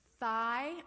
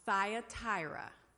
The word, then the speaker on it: Thyatira: Thigh, because we're used to this as being thigh, but it's thigh, like a thigh. Thyatira. Thyatira.